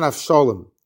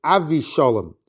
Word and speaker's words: Avishalom: And Tosfos says that the pronunciation and spelling is not Avshalom. It's Avishalom, because again, says Tosfos, we have this concept of Shem Rishayim Yerkov.